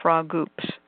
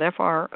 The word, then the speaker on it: frogoops: And that is what I call frog oops, and that is spelled FROGOOPS, frog oops. But it's all one word, frog oops.